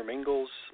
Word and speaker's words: Mingils: My name is Peter Mingles. Mingles is spelled M-I-N-G-I-L-S. And for those people that fumble over the pronunciation, it's like Mingles like Pringles, but there's no E in the word Mingles. So it's M-I-N-G-I-L-S.